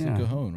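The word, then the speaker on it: cajón: Let's go through and meet everybody, since the entire band is here with three acoustic guitars and one cajon. Did I say that correctly? – Yeah, that's the cajon, right? – Yeah.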